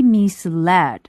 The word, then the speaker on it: misled: 这里的这个用法 be misled by something 是说被什么事情误导 misled M-I-S-L-E-D 它的原型是 mislead M-I-S-L-E-A-D 意思是误导那么在这句话当中用的是它的一个被动的形式 be misled 这里大家注意一下这个发音是 misled misled 那么是被什么样的事情所误导呢 The loss of a few pounds.